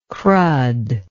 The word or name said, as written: crud